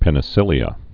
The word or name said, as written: penicillia